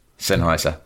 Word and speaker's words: Sennheiser: Is that how you say it? Sennheimer? – Sennheiser? – Sennheiser.